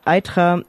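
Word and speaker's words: Eythra: Ich wollte auch nochmal erwähnen, dass eben dieses Dorf Itra, habe ich das jetzt richtig ausgesprochen? Egal. – Itra.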